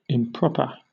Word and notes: improper: British English

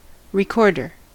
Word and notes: recorder: American English

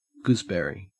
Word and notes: gooseberry: Australian English

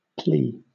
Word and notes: plea: British English

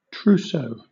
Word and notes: trousseau: British English

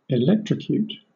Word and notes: electrocute: British English

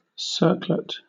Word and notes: circlet: British English

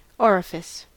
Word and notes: orifice: American English

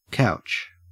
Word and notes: couch: Australian English

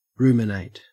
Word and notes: ruminate: Australian English

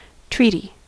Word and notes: treaty: American English

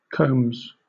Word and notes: combs: British English

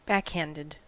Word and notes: backhanded: American English

